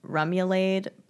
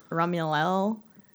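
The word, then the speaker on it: remoulade: It looks like Rumulade, but it's pronounced Rumulel.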